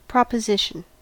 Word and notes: proposition: American English

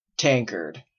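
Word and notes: tankard: Canadian English

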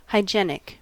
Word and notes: hygienic: American English

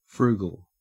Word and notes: frugal: Australian English

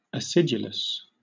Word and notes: acidulous: British English

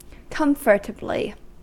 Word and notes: comfortably: American English

